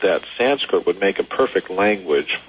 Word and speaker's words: Sanskrit: No, the word is actually, maybe I missed, haven't been pronouncing it quite clearly enough, but it's the word is Sanskrit, S-A-N-S-K-R-I-T, Skrid. – Not with a D in it, so yeah, I apologize for not pronouncing it correctly.